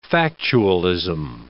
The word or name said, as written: factualism